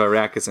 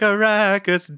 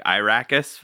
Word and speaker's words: Arrakis: Like Arrakis days. I actually just realized you said Arrakis kind of funny. You said it like Irakis funny.